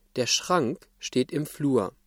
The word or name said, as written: Flur